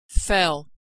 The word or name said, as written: fell